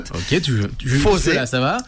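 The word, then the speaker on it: Fourze: Ça s'écrit Fours. Ça se prononce Fosé.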